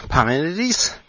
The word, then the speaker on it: Parmenides: I've polished off Parmenides.